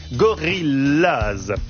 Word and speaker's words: Gorillaz: Gorillaz se prononce comme en anglais, Gorillaz.